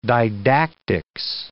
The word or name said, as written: didactics